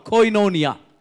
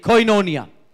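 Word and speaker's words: koinonia: இந்த க்ரீக் வார்த்தை வந்து கோயினோனியா இங்கிலீஷ்காரங்க வந்து கோயினோனியா அப்படின்வாங்க தமிழில் நம்ம படிக்கும்போது இது க்ரீக் வார்த்தையை நம்ம எப்படி ப்ரொனவுன்ஸ் பண்ணுறோம் கோயினோனியா கேஓஐ என்ஓ என்ஐஏ கோயினோனியா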